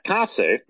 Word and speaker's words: Kase: Once again, that minister's last name is spelled K-A-S-E, Kase,